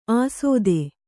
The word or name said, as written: ಆಸೋದೆ